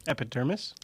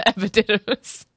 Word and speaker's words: epididymis: You locate the, I don't even know how to say this, but epididymis? – Epidermis? – No, that's your skin. – Mm-hmm. – Epididymis, it has an extra did, it has a did in there.